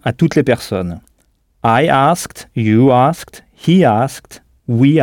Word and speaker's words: asked: He asked. – He asked, oui, qui s'écrit donc « a-s-k-e-d », mais qui se prononce comme une seule syllabe, avec le « ed » final prononcé « t ».